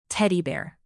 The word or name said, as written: teddy bear